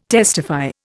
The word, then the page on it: testify: Indian